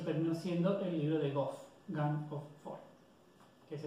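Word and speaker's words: GoF: Este es el libro que se conoce como el libro de Goff, o, sí, el libro de Goff normalmente, se dice así.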